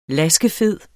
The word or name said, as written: LASKEFEDE